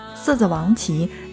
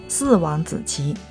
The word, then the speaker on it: 四子王旗: 说到格根塔拉，那么不得不介绍到的就是位于格根塔拉的这个地方，叫做四子王旗。千万千万别搞错哈，因为很多外地人呢不会念这个内蒙古的很多的地方，然后呢就会把四子王旗念成叫做四王子旗，千万千万别念错。